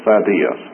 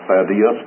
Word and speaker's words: Thaddeus: whose surname was Thaddeus. Now, it's difficult for me to pronounce that Thaddeus, because I've always pronounced Thaddeus, but in my edition, the accent is on that A-E.